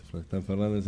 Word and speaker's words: Florestan Fernandes: la Escuela Nacional Florestan Fernández. ¿Lo digo bien? – Muy bien. – Listo, Florestan Fernández.